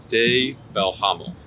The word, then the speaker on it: De Belhamel: Is that as in D-A-Y or is it D with... – It's more like De. Maybe I'm not saying it right. De Belhamel. Okay. I don't have as much of an accent, but I can spell it for you. So it's D-E space B-E-L. H-A-M-E-L. – Okay.